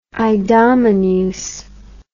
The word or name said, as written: idomeneus